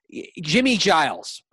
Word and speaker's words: Giles: It's always mispronounced, but it's pronounced Giles. Rhymes with Miles.